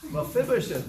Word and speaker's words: Mephibosheth: It's like Mephibosheth. Anna, you just earned 50 cents. Mephibosheth, very good.